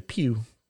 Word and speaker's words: Pugh: I think it should be Florence Pugh. Because I just learned how to say her name, and that's fun to say. Pew, pew, pew, pew.